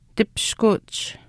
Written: tipishkuuch

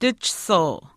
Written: tihchisiu

